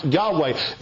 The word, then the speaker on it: Yahweh: YHWH, pronounced Yahweh.